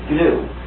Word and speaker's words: GNU: Anyway, when it's the name of our operating system, please pronounce the hard G. Pronounce it GNU. Say it 1, 2, 3, GNU. Because if you call it the new operating system, you'll get people very confused.